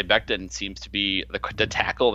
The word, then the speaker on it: Becton: Mekhi Becton, tackle Louisville. I think that's how you say his name. I don't even know how you say his name. – Becton, yeah. – Becton.